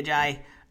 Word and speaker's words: Ajayi: Ajayi. – I was avoiding it. I think Jay Ajayi will go solid here.